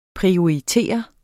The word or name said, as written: prioritere